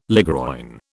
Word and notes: ligroin: British English

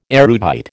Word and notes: erudite: American English

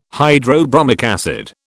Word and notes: hydrobromic acid: British English

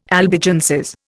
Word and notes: Albigenses: Indian

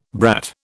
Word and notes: brat: British English

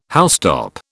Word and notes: housetop: British English

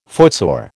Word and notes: footsore: American English

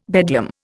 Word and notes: bedlam: Indian

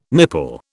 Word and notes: nipple: British English

